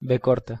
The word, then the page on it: v: Spanish